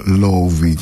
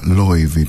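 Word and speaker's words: Laufey: Οι Λόουφοι λοιπόν που στην πατρίδα της στην Ισλανδία την διαβάζουν ως Λόουβι και μάλιστα Λόιβι πιο σωστά,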